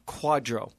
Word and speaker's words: QDRO: QDRO, and we pronounce it in the industry as quadro.